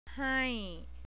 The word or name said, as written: hai